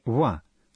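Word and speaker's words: ва (は): Когда мы пишем частицу «ва» хироганой, мы пользуемся слогом «ха», а не «ва», хотя произносим «ва».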